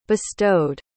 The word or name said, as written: bestowed